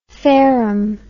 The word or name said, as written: Fareham